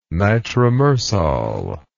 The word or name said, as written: nitromersol